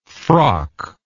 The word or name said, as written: frockless